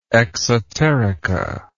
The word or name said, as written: exoterica